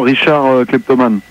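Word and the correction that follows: Richard Clayderman: Richard Klederman.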